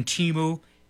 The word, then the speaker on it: Temu: Have you ever ordered anything from Temu, T-E-M-U? I think that's the proper way to pronounce that business.